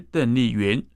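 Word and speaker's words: 邓丽筠: 邓丽君原名是邓丽云，云的意思是美丽竹子，但云字的下面有个君，很多人就将其念成君同音的君。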